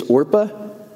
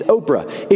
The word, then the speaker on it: Orpah: And by the way, did you know that Oprah Winfrey's real name is Orpah? It's just that people began mispronouncing it so much that she just adopted Oprah.